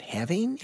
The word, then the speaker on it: halving: But I think having the dose, how do you pronounce that? – Halving. – But why it's not halving? – Halving the dose. – Get that L in there.